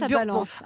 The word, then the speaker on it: Burdon: Écoutez, je vais balancer parce que j'ai demandé à Cyril Lacarrière comment prononcer, il m'a dit Burdon.